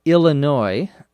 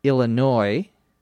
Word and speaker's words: Illinois: Illinois, I L L I N O I S, uh, is sometimes mispronounced as Illinois with an S sound. Illinois Illinois. The correct pronunciation, or the most common pronunciation, is Illinois with no S sound at the end.